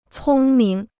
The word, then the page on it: 聪明: cōng ming